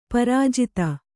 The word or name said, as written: ಪ್ರಜಾತೆ